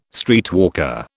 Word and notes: streetwalker: British English

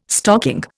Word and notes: stocking: Indian

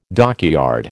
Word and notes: dockyard: American English

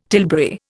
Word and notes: tilbury: Indian

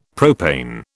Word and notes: propane: British English

